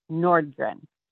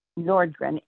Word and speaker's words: Nordgren: Arlita Nordgren. – And can you spell that for me? – A-R-L-E-T-A. Nordgren, N-O-R-D-G-R-E-N.